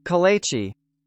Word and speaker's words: kolache: Kolache. Sound weird. – It does sound a little weird, but as far as I know, it is pronounced kolache.